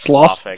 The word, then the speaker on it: sloughing: Well, the fact that your hands are slowing off doesn't really help. – Yeah. Then. – Is that how you pronounce that? – No, it's sloughing.